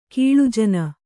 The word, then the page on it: ಕೀಳುಜನ: kannada